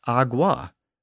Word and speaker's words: agua: Think about the Spanish word for water, A-G-U-A. It isn't pronounced agua, and it isn't even really pronounced agua. The G is barely pronounced at all, and it comes out more like agua.